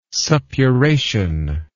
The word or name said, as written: suppuration